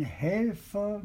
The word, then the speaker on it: Helfer: Da half mein Helfer mir vom Tod. Did you notice that I took the second syllable of helfer very lightly?